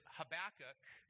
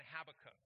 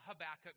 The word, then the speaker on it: Habakkuk: So today we're going to dive into a book of the Bible that is pronounced a couple different ways. There is potato, potato, tomato, tomato, and there is Habakkuk and Habakkuk. I'm a Habakkuk guy. You may be a Habakkuk person. That's fine.